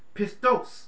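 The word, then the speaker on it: Pistos (πιστός): Pistos is how you pronounce that in the Greek.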